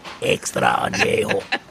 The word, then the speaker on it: añejo: Extra. Extra añejo. That's how you say it.